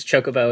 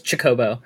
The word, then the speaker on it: Chocobo: You're the second person I've met that pronounces Chocobo as Chikobo. So I'm glad that you're not the only one. I'm glad I'm not, like, crazy and remembering that memory wrong in my head from, like, somebody I knew in high school that pronounced it Chikobo. So, no, thank you, Imran.